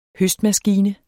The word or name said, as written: høstmaskine